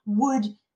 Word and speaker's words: would: And let us remember, Spanish speakers, we have a tendency of pronouncing would with pronouncing the W, no, would. We have to make sure if I would, there is air passing through our mouth, I would accept the offer.